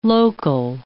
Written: local

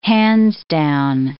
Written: hands-down

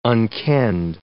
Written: unkenned

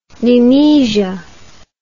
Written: nemesia

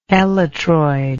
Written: elytroid

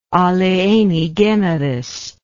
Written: alieni generis

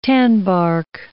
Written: tanbark

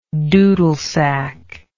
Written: doodlesack

